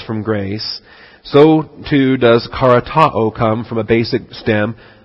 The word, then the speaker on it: charitoo: All right, karatao. C-H-A-R-I-T-O-O. It's got the O-O ending with the short O and the long O, the omicron and the omega.